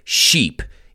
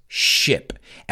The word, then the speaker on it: ship: But whatever you do, don't pronounce it sheep. It's ship. And remember, you can have a ship on the ocean.